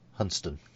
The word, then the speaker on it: Hunstanton: Yes, it is a wonderful coast with those beautiful high cliffs at Hunston. – Quick side note for people not familiar. Place names are not always pronounced as their spelling would suggest. Hunstanton is by the locals Hunston.